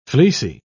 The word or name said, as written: fleecy